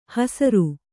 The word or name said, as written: ಹೆಸ್ಸರೆ